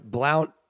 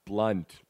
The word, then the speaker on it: Blount: This is like when the Patriots did it with Blount and... – What'd you just call him? – Blount. – Blount? – Is it LeGarrette Blount? – Blunt. Don't pronounce the U.